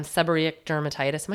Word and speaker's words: seborrheic dermatitis: um, seborrheic dermatitis.